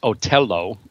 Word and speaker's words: Otello: And by the way, the Met opening night this year is a new production of, well, the opera is pronounced Otello, Othello, obviously, by Verdi, directed by Bartlett Sher.